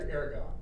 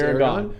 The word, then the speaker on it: Aragorn: You're acting like Aragorn. – It's Aragorn.